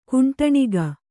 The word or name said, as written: ಕುಂಟಣಿಗ